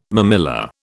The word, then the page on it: mamilla: British English